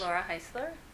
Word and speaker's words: Laura Heisler: Laura Heisler, L-A-U-R-A-H-E-I-S-L-E-R.